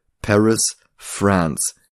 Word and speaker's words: Paris Franz: Also Franz wie der Vorname, aber englisch ausgesprochen klingt es wie France, Frankreich. Also Paris Franz.